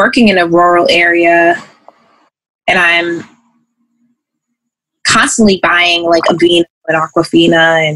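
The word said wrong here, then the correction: Aquaphor: And did I say that right? Aquafina? Aquaphora. – Is it Aquaphora? – Aquaphora. Aquafina is a water company.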